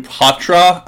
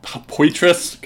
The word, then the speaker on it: Poitras: Oh, yeah, and I guess if we're going to be homers here, it's actually pronounced Matthew Patra. I was calling it Poitras because that's how you spell it.